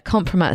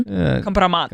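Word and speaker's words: kompromat: Yeah, compromat is such a good word. How do you say it in Russian? – Compromat. – Compromat.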